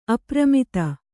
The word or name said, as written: ಅಪ್ರಮತ್ತೆ